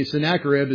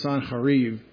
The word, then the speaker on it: Sennacherib: What's so surprising for me, Sennacherib is spelled with an A, S-A-N, and then it's really Sanharib. Sanharib would be how you read it in Hebrew. Funny how we got to Sennacherib in English.